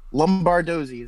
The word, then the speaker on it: Lombardozzi: Lombardozzi is how you say the name. – Okay. I didn't want to say it. I had to have you say it. – You don't want to butcher it. I get you. That's pretty phonetic. You know, it's pretty much as it's written, but I appreciate you asking before you went along and fucked it up.